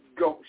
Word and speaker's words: gauche: And I'm going to spell it for you. That's G as in girl, A as in apple, U as in urgent, C as in child, H as in heaven, and E as in earl. And it's pronounced gauche.